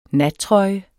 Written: nattrøje

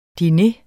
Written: DINEREN

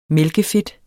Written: mælkefedt

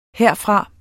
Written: herfra

